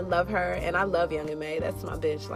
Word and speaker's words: Young M.A: Young Ma and Ari, what's up with that? – Young M.A. Okay, M.A., sorry.